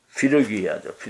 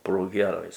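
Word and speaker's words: 프리기아: 갈라디아와 브로기아 땅을 차례로 떠나며 이제 갈라디아와 브로기아 그 땅들을 피르기아죠. 피르기아도 발음을 또 브로기아라고 했습니다.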